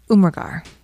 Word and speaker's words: Umrigar: And I looked up how to pronounce her name, so hopefully I'm doing this correctly. – Yes, 3T T H R I T Y, Umrigar. – All right, very good.